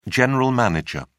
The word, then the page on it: general manager: British English